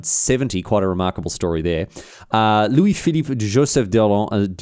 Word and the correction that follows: d'Orléans: butchered that one. I was going so well. Louis-Philippe Joseph d'Orléans. Orléans. D'Orléans. bloody hell, who would go on to become, the Duke of Orléans.